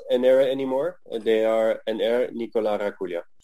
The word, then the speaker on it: Ennerre: And Ennerre is how you would pronounce the old brand from the late 80s, early 90s. Like Ennerre, it's like Ennerre, so Ennerre. That's how they would say it.